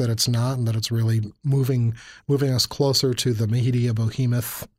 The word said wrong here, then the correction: behemoth: I'm sorry, let me try that again. Media behemoth. There, I said it right that time. Bohemoth sounds like a...